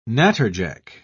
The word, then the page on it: natterjack: British English